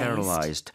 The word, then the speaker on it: Paralyzed: Paralyzed. 앞에 첫 번째 A의 강세를 좀 많이 주고 발음을 하셔야 됩니다.